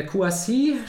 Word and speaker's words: Kouassi: Äh, Kouassi spricht man ihn aus.